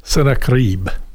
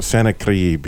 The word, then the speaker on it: Sennacherib: They could see there was a big old hand coming down in the name of, how do you say it, Sennacherib? You say it so well. – Now I won't. Sennacherib. – There you go. Sennacherib.